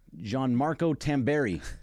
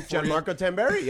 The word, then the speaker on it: Gianmarco Tamberi: Gianmarco Tamberi. I think I said that right. – That's the name. – Gianmarco Tamberi.